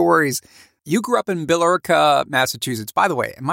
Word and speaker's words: Billerica: Yes, Billerica. Well, yeah, if you want to say it right, it's Billerica.